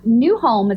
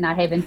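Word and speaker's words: Nyhavn: Nyholm is actually how Nyhaven is pronounced. – Oh. – And so, but I was calling it Nyhaven for a very long time as well.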